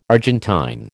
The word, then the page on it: Argentine: American English